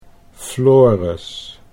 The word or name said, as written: Floris